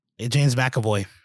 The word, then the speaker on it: McAvoy: McAvoy, I'm sorry. Or is that, am I saying that correctly? – James McAvoy.